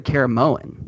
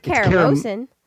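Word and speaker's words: Caramoan: Yeah, you just said Karamoan. – Yeah. Karamoa. No, Survivor it's not Karamosan. – No, Karamosa is the joke. I always say it wrong on purpose.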